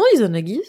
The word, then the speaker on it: Izanagi: Le héros c'est euh, Izanagi. J'ai peur de l'avoir mal prononcé, mais non non Izanagi c'est ça.